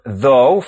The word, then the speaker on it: though: The O-U-G-H in though, the word in today's text, rhymes with toe. – Add a T to the end of though for thought, and the O-U-G-H sequence now rhymes with awe.